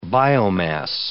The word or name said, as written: biomass